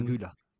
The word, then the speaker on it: Güler: Dann kam Arda Güler, habe ich das richtig ausgesprochen? – Arda Güler.